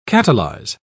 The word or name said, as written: catalyze